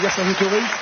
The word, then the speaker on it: Vercingétorix: Avec Vercingétorix. Statue équestre réalisée par Bartholdi et la statue de Dezet. Et on ne prononce pas le X à la fin.